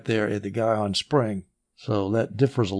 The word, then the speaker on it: Gihon: And I looked up how to pronounce Gihon. One YouTube video said it's Gihon. Another YouTube video said it's Gihon, which shows that there's nothing standard about pronunciation. I'm going to call it Gihon.